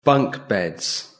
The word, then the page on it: bunk beds: British English